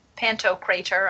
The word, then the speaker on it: Pantocrator: I mean, because the image, the Byzantine Christ image, this is Christ, the Pantocrator. I don't know how you say it. Is that how you say it? – Yeah. – So I think so.